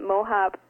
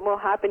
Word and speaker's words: Mohaupt: And it's kind of funny, too, because sometimes people think that Mohaupt has to do with the shoes, like to put Mohaupt in your step or something like that.